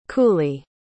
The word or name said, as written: cooley